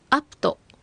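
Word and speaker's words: アㇷ゚ト: はい、そしてアプト、ここはプが小さいプの発音になりますので注意してください。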